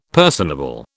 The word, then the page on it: personable: British English